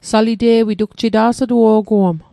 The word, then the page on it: salite'wit: Mi'gmaq